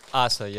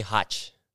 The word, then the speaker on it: Haza: Haza, yeah. – Yeah. How many people say Haza? – Most people say Hach. – Hach? – Yeah, it's weird.